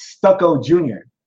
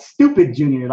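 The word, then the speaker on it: Stuka Jr.: It's Stucco Jr. Not Stupid Jr. Like I told y'all on pre-tape. Like I heard Stupid Jr. Like actually, Stucco.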